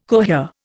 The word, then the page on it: cohere: Indian